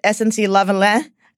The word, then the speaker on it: SNC-Lavalin: So I pronounce it SNC-Lavalin. It's a company in Quebec. I'm of French-Canadian descent, and my grandmother, who only spoke Canadian French for the first like 5 years of her life, would kill me if I didn't pronounce this properly.